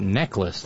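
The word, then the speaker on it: Necheles: Darlene says, I think Susan's name is actually pronounced necklace, like the jewelry.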